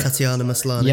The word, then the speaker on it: Tatiana Maslany: Tatiana Maslany.